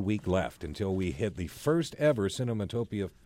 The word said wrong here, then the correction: Cinetopia: See, I keep saying it wrong. – Cinetopia. All right. – Cinetopia right.